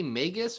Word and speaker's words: Magus: How do you say that? – I say Magus because I... – The Magus. – Because I'm pretty sure... It's based on the wizard from the Bible, Simon Magus.